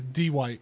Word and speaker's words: Dwight: Yeah, don't say it like that. It's Dwight.